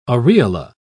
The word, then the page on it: aureola: British English